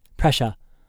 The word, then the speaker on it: Presha: He pronounced it Presha, like the song, he said.